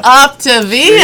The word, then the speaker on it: Optavia: Oh, Optavia. – Optavia.